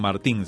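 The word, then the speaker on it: Martin: Más adelante, encontramos parejas como los siervos de Dios Luis y María, algunos dicen Martán, otros dicen Martín, se escribe Martín, Luis y María Martín.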